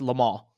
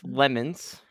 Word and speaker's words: Le Mans: First of all, I got to get you to say it right. It's Le Mans. – Le Mans. Okay. – Secondly, sorry, I'm so used to saying 24 hours of lemons.